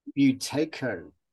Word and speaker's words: Buteyko: He's a certified, how do you say that? – Buteyko. – There's a certified Buteyko method instructor and an Oxygen Advantage advanced instructor.